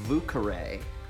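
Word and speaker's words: Vieux Carré: It's called the Vucare. That is a French word that is spelled V-I-E-U-X-C-A-R-R-E.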